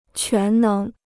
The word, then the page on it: 全能: quán néng